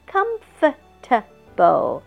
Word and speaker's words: comfortable: OK, 所以 hose 这个字我们一定要会, 因为是洞, 下一个字 comfort, comfort 是舒适, comfortable, comfort 是舒适, 加一个 able 就变哦舒适的 comfortable comfortable 一加了 able 之后它的发音就变有四段了 comfortable 但是高音还是在第一段